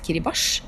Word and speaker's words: Kiribati: er fra Kiribati, eller som jeg har lært nå heter uttales Kiribash, som er et land i ja, sånn, Stillehavet.